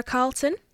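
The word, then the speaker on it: Carleton: My name is Clara Carlton. – Carly, could you spell that for me, please? – Sure. It's C-A-R-L-E-T-O-N.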